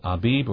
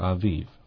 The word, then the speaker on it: Aviv: Glenn Jen Robbins says, quick question, what's the proper pronunciation of Aviv? Is it Abib or Aviv? I thought the Hebrew letter Bet was pronounced as a V only by tradition. Well, it is. There's a dogish in the center of it. It would be pronounced with the harder sound, the B. If there's not a dogish in the center of Aviv, then it would be pronounced with a lighter V.